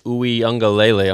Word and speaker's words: Uiagalelei: Quarterback DJ Uiagalelei.